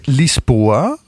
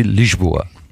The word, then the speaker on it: Lisboa: Oder auf Portugiesisch wahrscheinlich richtig ausgesprochen, so ähnlich wie Lisboa.